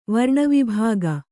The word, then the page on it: ವರ್ಣವಿಭಾಗ: varṇa vibhāga